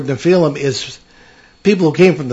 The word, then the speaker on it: Nephilim: So the Nephilim, or Nephilim, as it's sometimes pronounced,